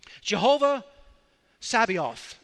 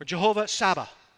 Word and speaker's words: Jehovah Sabaoth: This is one of God's names, and it's pronounced different ways. Jehovah Sabaoth is one of the ways. Or Jehovah Saba is another way.